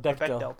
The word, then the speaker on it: Bechdel: Bechdel test, yeah.